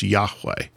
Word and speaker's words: Yahweh: We don't know how it's pronounced, but we pronounce Yahweh.